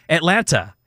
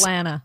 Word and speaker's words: Atlanta: you pronounce the last T in Atlanta. – Yes. – So I got here, and I was saying Atlanta. Well, now 6 years me been here, Nobody pronounces that last T.